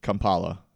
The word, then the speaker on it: Kampala: Offline, the Constitutional Court was petitioned to reverse it, and a brief street protest was held in Kampala. Is that how you pronounce that? – Yep, okay. You got it right.